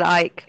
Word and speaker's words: Icke: Is it Icke or Ike? – I always said Ike. – Is it Ike? Okay. – Yeah. – Okay, Ike.